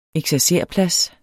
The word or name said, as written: eksercerplads